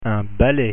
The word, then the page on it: balai: French